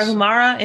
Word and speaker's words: Tarahumara: How do you say that? Tarahumara? Tarahumara? Does that sound good, Melissa? – Sounds good. Yes. Tarahumara Indian.